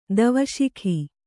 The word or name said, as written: ಧೀವಸಿಗ